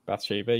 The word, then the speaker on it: Bathsheba: Some bullet points I've written is Bathsheba. Is that how you say it? – Bathsheba, yeah.